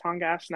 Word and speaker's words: Tongass: And there's a national forest there called, I'm pretty sure it's pronounced Tongass National Forest.